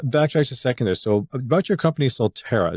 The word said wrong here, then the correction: Soltara: is that how you pronounce it solterra Soltara. – solterra okay sorry if you can just explain yeah.